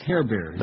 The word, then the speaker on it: Herber: Johannes Hairbear. His name is German. H-E-R-B-E-R. Plays basketball for West Virginia. But his name is pronounced Hairbear.